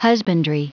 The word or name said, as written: husbandry